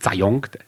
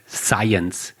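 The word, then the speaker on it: Zajonc: Science schreibt sich Zayong, ja, vorne mit Z und hinten mit C, der wird immer falsch ausgesprochen. Der heißt nicht Zayong, Science, Robert Science.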